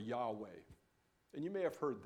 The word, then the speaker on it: Yahweh: It's usually pronounced Yahweh. In some languages today, it's pronounced Jehovah.